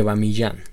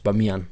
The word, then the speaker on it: Bamiyan: Yo en Buda explotó por vergüenza dije Buda de Bamillán, los Budas del Bamillán. Y, y la pronunciación correcta de la ciudad es Bamiyan.